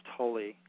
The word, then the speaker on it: Tolle: actually it's pronounced Tolle, Tolle.